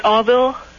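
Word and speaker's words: Adil: It's a A-D-I-L. – And how do you pronounce this name? – It's Avil. It's a African. – A-V-I-L? – A-D. – And it's Avil? – Yeah. – Wow. – So that the D is pronounced like a V? – More like a T-H sound, kind of, but yeah.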